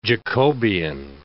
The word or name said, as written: Jacobian